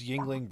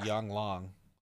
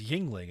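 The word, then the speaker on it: Yuengling: I mean, we're talking about a man who pronounced Yingling Brewery Yang Long.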